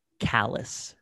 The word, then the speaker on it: Callas: C-A-L-L-A-S, pronounced Callas. A lot of Hispanic Class. Viewers, they think it's Callas. – Oh, Callas. Oh, yeah. And it is if you want it to be.